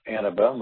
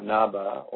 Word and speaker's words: Annabelle: And if you're listening to this message, that if you'd like to hear or see more information about Annabelle, first of all, its pronunciation is Annabelle, which you may not have known. Even people coming to our tasting room, and even my brother still calls it Annabelle or Annaba or what have you,